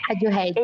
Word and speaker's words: Radiohead: Então, no Tá Rolando desta semana, o projeto Stay Home do Radical Head. Não sei se eu falei certo, gente, mas tá ok. – Radiohead. – Em uma atitude... É, Radiohead. Isso aí.